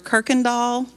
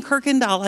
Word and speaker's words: Kuykendall: I'm the third daughter of Ernest and Barbara Kirkendall. Try growing up with that last name. And especially when it's spelled K-U-Y-K-E-N-D-A-L-L. How does that mean Kirkendall?